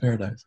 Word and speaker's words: Paradise: Yeah, I started putting it in my bio for all my social media. Like, hey guys, it's pronounced Paradise.